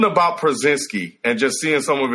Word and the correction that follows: Porzingis: Wait, did I say it wrong? – He said Przinski. – What is it? Old man, so Porzingis.